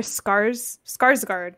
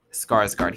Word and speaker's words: Skarsgård: Alexander Skarsgård, I believe is how you say it. – Yes, Skarsgård.